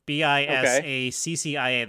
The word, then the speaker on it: Bisaccia: Bisciata? I pronounced that wrong. Bisaccia.